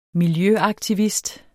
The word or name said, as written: miljøaktivist